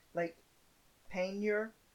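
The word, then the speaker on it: Lake Peigneur: Texaco Corporation has an oil rig drilling into Lake, and I actually wrote this down phonetically so I would not screw it up too badly. Lake Panier. I kid you not, that's how it's pronounced.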